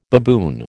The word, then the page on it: baboon: American English